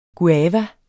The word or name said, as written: guava